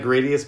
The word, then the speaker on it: Gradius: Yeah, there's only one boss that repeats in the original Gradius, and people are going to yell at me probably for pronouncing it Gradius, because I think it's actually Gradius.